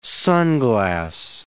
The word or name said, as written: sunglass